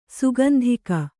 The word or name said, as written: ಸುಗಂಧಿಕ